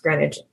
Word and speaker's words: Greenwich: I know it's spelled Greenwich, but it's pronounced Greenwich. It got me too, lady love.